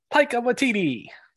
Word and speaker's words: Taika Waititi: what Taki uh, Taki with how do you fucking say his name? – Taika Waititi.